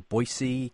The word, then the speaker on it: Boyce: Is it Boyce or Boise College? – Boyce College. Boyce and College.